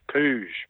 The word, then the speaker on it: Puig: P-U-G-H. – P-U-I-G. – Ah, P-U-I-G. – Yeah. So, and he's from the southern part of France, so he's almost Spanish, and the Spanish pronounce it Puig. And I think in some of the clips I've seen, it's Puig. But Frank Hyde, who should know, says Pooge or Bear.